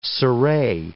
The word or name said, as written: cire